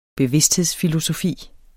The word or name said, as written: bevidsthedsfilosofi